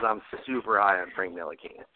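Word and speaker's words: Ntilikina: Nilakina. – Nilakina, yeah.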